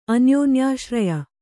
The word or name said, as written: ಅನ್ಯೋನ್ಯಾಶ್ರಯ